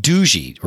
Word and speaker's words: Doogee: Is a company, they're the phones from a company called Doogee, or I think that's how we're supposed to pronounce it, D-O-O-G-E-E.